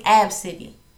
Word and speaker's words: Abcde: That's how it's spelled. And her name is Ab City. It's pronounced Ab. And then city, like the city of Savannah.